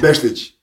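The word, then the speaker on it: Bešlić: Hur uttalar man det korrekt? – Beshlik.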